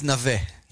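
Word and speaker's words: Naveh (נווה): It's Tzion Leshem of Naveh. Is that the right pronunciation? You keep saying Naveh. – Whatever. I'm Israeli, so Naveh.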